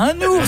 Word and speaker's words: un ours: On dit bien un ours, ça s'écrit N-O-U-R-S. – On fait la liaison. – Un narval. – Un oiseau, N-O-I-V.